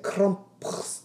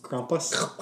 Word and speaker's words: Krampus: Krampus. – Krampus.